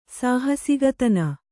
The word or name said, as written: ಸಾಹಸಿಗತನ